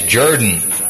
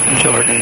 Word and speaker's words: Jordan: The guy said, how can you trust a man who pronounces the name Jordan as Jordan? – Jordan as Jordan. – Yes. Talking about Hamilton Jordan, spelled J-O-R-D-A-N, like the river.